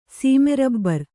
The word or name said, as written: ಸೀಮೆರಬ್ಬರ್